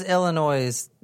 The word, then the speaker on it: Illinois: Illinois. – You don't pronounce the S at the end.